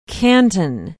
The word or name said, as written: canton